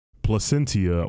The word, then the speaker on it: Placentia: Cottonmouth Kings is an American hip-hop group formed in Placenta, Orange County. How do you pronounce that? Placentia, Orange County, California.